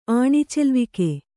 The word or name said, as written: ಆಣಿಚೆಲ್ವಿಕೆ